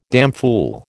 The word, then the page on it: damfool: American English